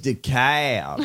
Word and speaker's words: DeKalb: I know it's weird because usually in the South you would stress the first syllable like July or insurance. But this is DeKalb.